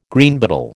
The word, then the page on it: Greenbottle: American English